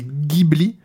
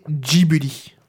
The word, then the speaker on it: Ghibli: Donc la prononciation originale italienne, c'est Ghibli, mais à la japonaise, c'est Ghibli.